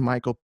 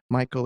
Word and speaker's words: Micheal: It's Jordan Michael Peterson. Michael is spelled M-I-C-H-E-A-L for some reason.